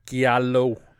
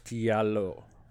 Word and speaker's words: giallo: And for many years, yeah, I would have said Giallo, but it's Giallo.